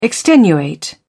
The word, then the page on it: extenuate: British English